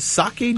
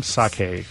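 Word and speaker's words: Sakae: Sake drums? – Yeah, I've heard it pronounced a couple of different ways, but I think sake. Sake. – Sake. – Cool. – It's like sake.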